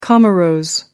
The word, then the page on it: comoros: American English